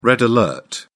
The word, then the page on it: red alert: British English